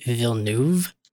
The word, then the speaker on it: Villeneuve: and they went with Denis Villeneuve. – Villeneuve? – Yeah, like two syllables. – Oh, so there's not that middle one.